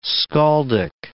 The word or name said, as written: skaldic